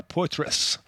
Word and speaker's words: Poitras: En français, c'est Laura Poitras, c'est une c'est une dame qui vit en anglais, donc c'est Laura Poitras. C'est comme ça qu'elle prononce.